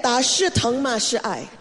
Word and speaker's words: 打是疼，骂是爱: We say that 打是疼，骂是爱。 – 一个错误的想法就是这个。 – Did I say it right? Okay. – Yes, that's right.